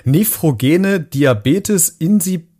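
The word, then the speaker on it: nephrogener Diabetes insipidus: Nephrogene Diabetes insipidus Pidus, ist es richtig, was ich gerade gesagt habe? Ja, das ist, ist, das ist richtig.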